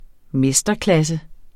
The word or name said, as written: mesterklasse